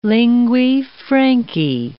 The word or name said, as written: linguae francae